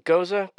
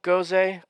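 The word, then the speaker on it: Gose: And what's the, is it Goza, Goze, Goze? How do you say that? – I've always heard I believe the proper pronunciation is Goza. That's what Goza. I hear of from a German.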